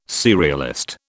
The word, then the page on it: serialist: British English